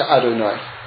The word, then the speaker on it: Adonai: Now there's another name of Hashem that we use, which is, it's spelled Aleph Dalet Nun Yud. And it's also pronounced Adonai.